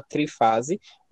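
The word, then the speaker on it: Triphase: ele se chama Léo Teixeira e o EP se chama Trifase, mas se escreve Trifase, né, T-R-I-P-H-A-S-E, e tem três músicas e, enfim, eu tô no trabalho dele porque as músicas são muito boas, né?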